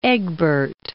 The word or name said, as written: Egbert